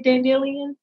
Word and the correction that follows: dandelion: Your dandelion.